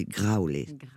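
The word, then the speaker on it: Graule: Oui, mais ça s'écrit pas pareil, parce que comme c'est catalan, le mien de nom, ça, ça se dit Graulé.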